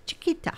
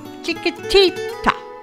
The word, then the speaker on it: Chiquitita: This is ABBA, and it is Chiquita. Chiquitita.